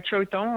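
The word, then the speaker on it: Kyauktan: Vous dites dans votre petit dictionnaire, vous parlez d'un endroit qui s'appelle, alors je sais pas si je prononce bien, Kiaoutan. – Chautang, oui.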